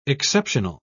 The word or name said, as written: exceptional